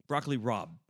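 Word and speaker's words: broccoli rabe: Broccoli Rob. I believe in pronouncing it correct.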